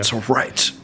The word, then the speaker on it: Prophets of Rage: Prophets of Rage.